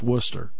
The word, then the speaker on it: Worcester: Nathan Davis joining us on the road somewhere in Massachusetts, closing in on Worcester, or as they would say there, Worcester. I've heard that pronounced so many different ways. Worcester, Worcester, it's Worcester.